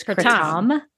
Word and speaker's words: kratom: I've always called it kratom. And we talked about how that sounds kind of scary. You're calling it kratom. – Yeah. – You also said the correct pronunciation. I feel like we got to set the record straight before I can go further. Is kratom. – Kratom, yeah, or something closer to that.